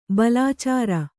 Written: ಬಾಲಚಾರ

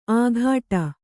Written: ಆಘಾಟ

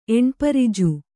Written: ಎಣ್ಪರಿಜು

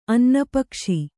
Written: ಅನ್ನಪಕ್ಷಿ

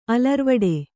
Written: ಅಲರ್ವಡೆ

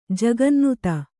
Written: ಜಗನ್ನುತ

